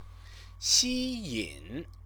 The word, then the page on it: xiyin: Chinese